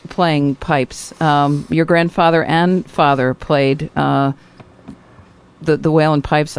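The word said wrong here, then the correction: uilleann: It's U-I-L-L-E-A-N-N, and it's pronounced Illan, and Illan is the Irish for elbow,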